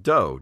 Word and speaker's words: Do: Since the theme of the company is retro, the 8-bit is referenced to classic games, and I believe the do in the name is pronounced like do, D-O-E, as in a martial arts school.